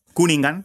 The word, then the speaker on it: Cunningham: Sí, porque yo decía Laurie Cunningham, porque mucha gente le conoce como Cunningham, pero realmente se dice Cunningham.